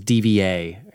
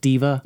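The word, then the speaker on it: D.Va: I thought it was DVA. Is it pronounced Diva? – Yeah.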